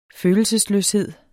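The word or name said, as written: følelsesløshed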